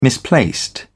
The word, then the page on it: misplaced: British English